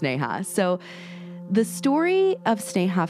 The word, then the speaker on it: Sneha: Now, I want to say, Britt, starting out, I've heard some people pronounce her name Sneha, but I knew somebody whose name was spelled exactly the same way, and she pronounced it Sneha, so I'm going to pronounce it Sneha.